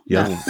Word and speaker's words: Jung: Jung.